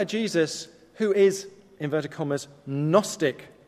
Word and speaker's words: Gnostic: However, the Gnostic, the G is silent, the Gnostic Gospels from Nag Hammadi, where the G is not silent, make Jesus less, not more human.